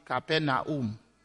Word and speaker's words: Capernaum: Today we are going to be talking about what we call here Capernaum. In Cameroon, we pronounced it Capernaum. And it seems to me the pronunciation in Cameroon stays in line with the phonetics, Capernaum, from the Greek and the Hebrew.